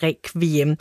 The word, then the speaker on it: requiem: Og at stave requiem med KV svarer til den danske udtale, som vi bruger sådan i daglig tale i Danmark, altså som vi gør lige nu, mens vi taler om emnet, vi siger requiem.